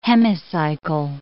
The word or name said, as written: hemicycle